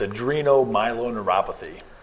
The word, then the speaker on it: adrenomyeloneuropathy: It's adrenomyeloneuropathy.